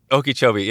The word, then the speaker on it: Okeechobee: Okeechobee, yeah.